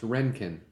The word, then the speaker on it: Röntgen: Oh, it's pronounced Röntgen.